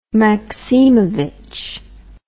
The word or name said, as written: Maksimovich